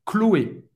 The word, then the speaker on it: Chloé: Agora, você não vai pronunciar como, lembrando, Chanel, CH, você vai falar sh, mas agora você vai falar Chloé, não vai pronunciar sh no final. Chloé. Pode tirar esse H na pronúncia. Chloé. Duas sílabas.